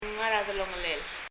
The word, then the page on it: ngar er a delongelel: ŋər ə ðɛlɔŋɛlɛl